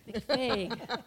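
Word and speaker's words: McFague: that I got onto, and you've talked about her, Sally McFaggy. And McFaig. – I always say that wrong.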